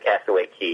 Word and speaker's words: Castaway Cay: I tell you what, they pronounce it Castaway Cay. It looks like K.